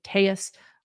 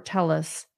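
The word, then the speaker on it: Telles: People in El Paso still use the Spanish pronunciation Tellis for the family name. But during his time in Las Vegas, Robert Tellis pronounced his last name Tellis.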